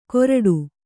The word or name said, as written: ಕೋರ್ಟು